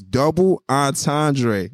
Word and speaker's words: Double Entendre: It's double entendre. – Entendre. – Okay? But it's spelled double entendre. So you can call me either one.